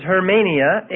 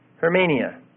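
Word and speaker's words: hermeneia (ἑρμηνεία): The Greek for interpretation is hermeneia, H-E-R-M, long A, N-I-A, hermeneia.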